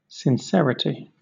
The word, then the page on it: sincerity: British English